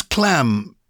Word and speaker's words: clamb: So does clam is the past tense of climb? – It is, C-L-A-M-B, yeah. But as you say, we still pronounce the B on that, making it a right hodgepodge, really. – And is there another clam, as in C-L-A-M, in the world of climbing? – Oh, in the world of climbing, no.